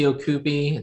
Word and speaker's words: Ocupe: It's Casey Ocupe. It's Filipino.